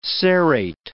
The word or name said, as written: serrate